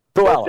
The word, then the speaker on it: Puyallup: Puyallup, Puyallup, Washington. Am I saying that right? Uh, pretty close. Okay. Puyallup. – Puyallup.